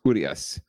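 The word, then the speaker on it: Urías: I think the accent is on the U. So it's Urias.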